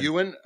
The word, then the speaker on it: Ewan: Ewan. Okay. Ewan McGregor. – I like Ewan better, dude. Like, it's close enough to Ewok. – Well, I was ready to say Ewan because I'm like, some people would say Ewan.